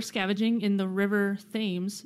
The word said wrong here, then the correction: Thames: Is that how you pronounce that? – I think it's the Thames. – Thames? I'm like, that's not how you pronounce that. That's how it's spelled.